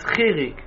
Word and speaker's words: chirik: If now you're going to teach him what's that, it's chirik. You pronounce it E.